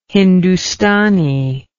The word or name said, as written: hindoostani